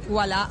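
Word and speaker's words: Ualá: Pero en la práctica, ustedes cargan su aplicación, UALA se escribe UALA, abre su cuenta, y empieza a usar Walla.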